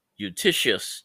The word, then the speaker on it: Eutyches: On the other hand, Eutychius, it's spelled E-U-T-Y-C-H-E-S,